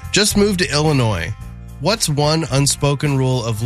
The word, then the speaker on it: Illinois: Mitchell said, the S in Illinois is silent.